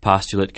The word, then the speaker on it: postulate: What's more, postulate can be used as a verb, too, only it's pronounced postulate.